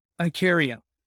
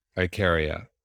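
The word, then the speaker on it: Ikaria: And is it Icaria? I can never pronounce it. Is it Icaria or Icaria? How do I pronounce it? – Icaria. – Icaria.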